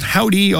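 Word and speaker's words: Hovvdy: It's a band called Howdy, although it's spelled with two V's and no W.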